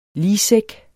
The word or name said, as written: ligsæk